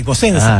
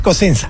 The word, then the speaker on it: Cosenza: No, porque es con S, Cosenza. – Ah, Cosenza. – Si fuera con C, sí, pero es con S, entonces es Cosenza.